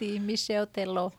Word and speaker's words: Michel Teló: Antes pondremos una canción de un brasileño muy reconocido mundialmente, que es Roberto Carlos, que canta junto con el Michel Teló. No sé si lo pronunció bien, ¿lo pronunció bien o no? – Sí, Michel Teló.